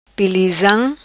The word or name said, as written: Pilizam